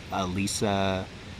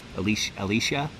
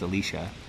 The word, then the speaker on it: Alicia: If you guys haven't seen it, it's got Oscar Isaac and Domhnall Gleeson and Alyssa Vikander, I believe is how you pronounce her name. Alicia? I think it's Alicia. I mispronounced it.